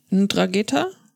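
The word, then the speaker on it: 'Ndrangheta: Ein Drageta? Ja, genau. Okay. Spricht man das so aus? Ja.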